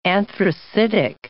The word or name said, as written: anthracitic